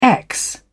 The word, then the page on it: ex: British English